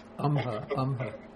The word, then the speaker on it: amha: I think it should be asma, with a long A. I'd have to look that up. Asma. No, this is correct. Amha.